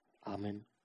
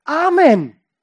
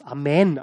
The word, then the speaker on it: Amen: sagt die Gemeinde Amen. Nein, sie sagt eigentlich Amen. Oder Hebräisch spricht man es aus Amen, also die Betonung auf die zweite Silbe.